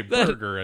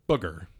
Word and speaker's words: Booger: You did say burger and not booger. – No, I said booger. – Okay. – Booger. – Booger. With the long O.